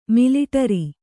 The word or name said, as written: ಮಿಲಿಟರಿ